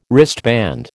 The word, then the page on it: wristband: American English